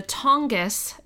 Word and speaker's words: Tongass: Ketchikan is surrounded by the, okay, now I'm going to pronounce it correctly, surrounded by the Tongass National Forest, the largest national forest, the largest national forest at nearly 17 million acres.